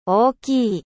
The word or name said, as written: grand